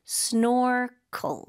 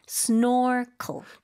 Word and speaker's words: snorkel: 네, 영어로는 이음절로 발음해요. Snorkel. 마지막 cl, 짧게. Snorkel.